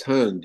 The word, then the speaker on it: Hund: Bud, did I get your last name right? Because I didn't ask you ahead of time how to pronounce it. – It's Hund, yes.